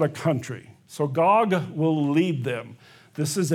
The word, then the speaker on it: Gog: You know, did they mispronounce it? It's supposed to be D, God? No, it is Gog, G-O-G.